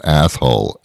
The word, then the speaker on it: Athol: There's a town up here called Athol. A-T-H-O-L. If you pronounce it quickly, it sounds like yeah. Yeah, like asshole.